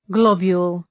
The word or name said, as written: globule